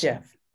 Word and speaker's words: GIF: No, you're in, you're incorrect. It's it's it's Jeff.